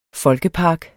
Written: folkepark

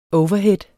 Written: overhead

